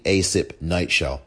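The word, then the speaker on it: Asip-Kneitschel: And her last name is spelled A-S-I-P hyphen K-N-E-I-T-S, as in Sam, C-H-E-L. Stacey Asip Neitschel.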